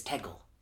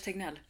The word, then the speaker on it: Tegnell: The public health agent is Tegel. Anders Tegnell.